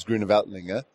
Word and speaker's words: Grüner Veltliner: Obviously, the big grape variety from Austria is Grüner Veltliner. Have I pronounced it right? – Perfect. You are really an expert already, you know.